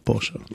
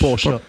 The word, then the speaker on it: Porsche: Porsche, là, Porsche. – Tu dis Porsche, déjà Porsche. Porsche. Ouais, Porsche. Tu roules un peu le R déjà.